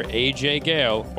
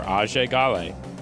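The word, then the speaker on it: Ajay Ghale: But Far Cry 4, you play as, depending on how you pronounce it, either AJ Gale or Ajay Gale, because you are part American, part karate man who is headed to the nation of Karat, which is really just Nepal, right?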